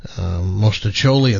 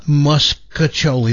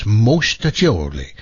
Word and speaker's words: mostaccioli: If you're a toasted ravioli person or a mostaccioli, and that's how you pronounce it, not muscaccioli. There's no K in there. There's no U. It's mostaccioli.